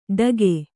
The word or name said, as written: ಢಗೆ